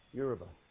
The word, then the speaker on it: Yoruba: Because their heart languages may be Yoruba. Maybe it's Afrikaans. Did I say it wrong? All right. Well, how do you say it? Yoruba.